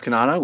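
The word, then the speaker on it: Cannata: I am at Jeff Kanata, which is spelled with two N's and one T, on Twitter.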